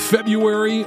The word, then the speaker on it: February: Do you pronounce this month February or the correct way? February. No, it's ridiculous. February. I think it's time we get rid of the R.